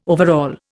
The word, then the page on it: overall: Indian